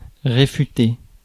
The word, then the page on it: réfuter: France; [ʁe.fy.te]